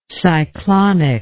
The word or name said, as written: cyclonic